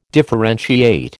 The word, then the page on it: differentiate: American English